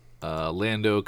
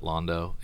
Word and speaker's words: Lando: Now we got a new Star Wars, Lando Calrissian. – Of course, the Age of Republic one shots, which a lot of them have been really cool and good. – Don't pronounce it Londo. It's Lando.